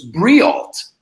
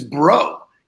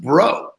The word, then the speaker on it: Breault: And his last name is spelled B-R-E-A-U-L-T. And I always thought for all of, I know him 10 years, I thought his name was Briault, Scott Briault. Well, he comes on my podcast and he says his name is Bro. It's pronounced Bro. And I thought he was pulling my leg. I'm going, yeah, we're all bros. Whatever. He goes, no, it's Bro.